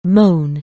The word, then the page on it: moan: anglais